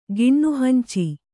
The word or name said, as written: ಗಿಣ್ಣುಹಂಚಿ